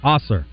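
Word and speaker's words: Ausar: Is it Amen Thompson and Asar Thompson? Is that how you say it? – Asar. – Asar. Okay. Is it A-M-E-N?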